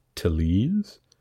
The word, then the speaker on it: Tellez: One thing we learned was how to pronounce his name. It is Tellez, according to one man.